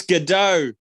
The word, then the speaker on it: Gadot: It's Gadot. It's Gadot. The T is silent.